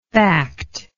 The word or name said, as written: factful